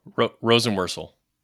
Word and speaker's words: Rosenworcel: I'm going to butcher the last name, Jessica Rosenworcel.